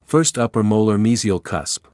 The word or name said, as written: first upper molar mesial cusp